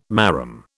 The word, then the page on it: marram: British English